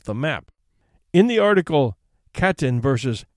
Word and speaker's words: Katyn: Katyn, which is pronounced as written, is a town about 15 miles west of Smolensk.